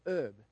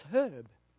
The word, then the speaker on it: herb: I was told this morning in America you say herb. Where I come from, it's not a silent H, it's herb.